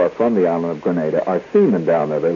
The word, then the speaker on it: Grenada: Don't pronounce the island Granada. Granada is a town in Spain. It has nothing to do with Grenada,